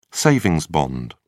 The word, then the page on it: savings bond: British English